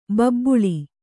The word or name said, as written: ಬೆಬ್ಬಳಿ